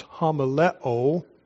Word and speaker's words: homileo: The word is pronounced homileo, from which we get the word homiletics.